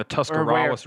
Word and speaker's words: Tuscarawas: Right along the Tuscarawas River. – Ooh, nice pronunciation.